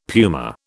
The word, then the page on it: puma: British English